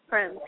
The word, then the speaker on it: Prem: Yes, Prem, P-R-E-M.